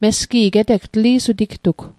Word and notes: tli'suti: Mi'gmaq

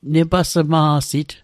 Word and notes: nepasma'sit: Mi'gmaq

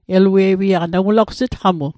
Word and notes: elue'wiaq: Mi'gmaq

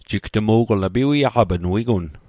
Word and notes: piwiaq: Mi'gmaq

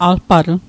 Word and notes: alpatl: Mi'gmaq

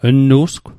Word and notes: lnu'sgw: Mi'gmaq

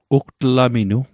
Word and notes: ugtlaminu: Mi'gmaq

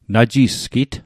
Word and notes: najisgit: Mi'gmaq

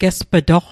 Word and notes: gesp'toq: Mi'gmaq

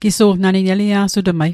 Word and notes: Gisu'lgw: Mi'gmaq

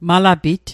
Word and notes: malapit: Mi'gmaq

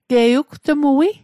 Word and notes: wetmuatl: Mi'gmaq

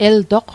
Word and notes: eltoq: el'toq; Mi'gmaq

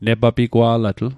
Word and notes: negapigwa'latl: Mi'gmaq

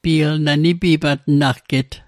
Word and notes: nipimatnagget: Mi'gmaq

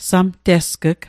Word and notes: samtesg'g: Mi'gmaq

